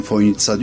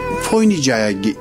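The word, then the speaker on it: Fojnica: Ama çözemediğim şey, telaffuz olarak ben Foynitsa diyorum, öyle duydum. Fakat gelen misafirlerin hepsi o C'yi S olduğunu bilmiyor, TS olduğunu bilmiyor ve Foynica'ya işte gelmekten şeref duydum gibi şeyler zikrediyor. Merkeze bir yazı yazdım. Dedim ki Foynici olarak yazılan kelime telaffuzu Foynitsa şeklindedir, düzeltilmesi.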